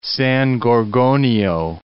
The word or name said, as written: San Bernardino Mountains